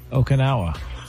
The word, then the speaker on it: Okinawa: Okinawa? – Okinawa.